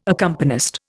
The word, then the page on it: accompanist: Indian